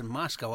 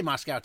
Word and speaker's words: Moscow: I heard they pronounce it Moscow over there. I remember someone telling me there's no cow in Moscow.